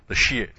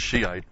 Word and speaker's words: Shia: the special shrine for the Shias. And the Shias, I guess they call them. It looks to me like it should be pronounced Shiite, but they call it Shia.